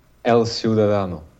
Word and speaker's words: El Ciudadano: El Ciudadano.